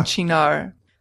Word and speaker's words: Cynar: It's spelled C-Y-N-A-R. And Chinar. I listened to a pronunciation, and it was an Italian woman saying Chinar.